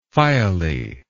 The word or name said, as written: phiale